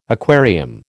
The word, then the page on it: aquarium: American English